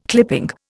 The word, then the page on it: clipping: Indian